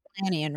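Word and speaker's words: Dilanian: Here's what I think Ken Donilon from NBC wrote. – Delanian, right? – What's it? How's it pronounced? – Delanian.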